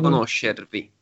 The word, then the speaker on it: conoscervi: Di conoscervi. – Piacere di conoscervi. – Conoscervi. – Ah, piacere di conoscervi. – Perfetto. – Aha, conoscervi. Das heißt, die Betonung ist nicht auf die letzte Silbe.